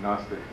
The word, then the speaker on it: Gnostic: In Gnostic Christianity, is that how you pronounce it? Gnostic? – Gnostic. Yeah.